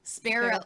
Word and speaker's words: Spero: Tell everyone your promo code. – S-P-E-R-O. – Spiro. Spiro. I've been saying it wrong.